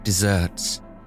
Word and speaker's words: deserts: The cruel and unkind earning their just deserts. Now, that phrase is older than dessert, don't you know? It's spelt the same as the sandy wasteland, but pronounced like the sweet treat.